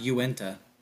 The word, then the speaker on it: Uintah: And this thing's saying it's pronounced Uintah.